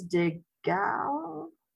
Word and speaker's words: De Gaulle: And afterwards, General Charles de Gaulle, the free French leader and future president, visited the city.